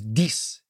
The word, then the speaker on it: dix: And the $10 note was dix, D-I-X, in French, written on the note. Pronounced D-E-E-C-E in English, dix, or in French. But of course, English speakers couldn't pronounce dix. So they called it Dix.